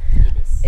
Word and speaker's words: Ibis: So now, is it Ibis or Ibis? – Ibis. – Ibis, okay. Everyone pronounces it differently. So I'm like, what the hell is that Scandinavian word? So now, Ibis, as I was originally calling it, and then everyone corrected me to Ibis, but now you're saying Ibis.